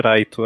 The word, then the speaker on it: Light: É o mesmo motivo que eu chamo o protagonista do Death Note de Raito ao invés de Light. – Então, eu também chamo de Raito também. – É, a pronúncia japonesa.